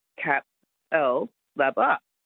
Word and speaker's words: Capalaba: Because that's how, if you look at it, it's C-A-P-A-L-B-A, Cap-L-Laba.